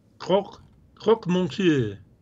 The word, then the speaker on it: croque monsieur: Croque monsieur. Did I say that right? – Yep.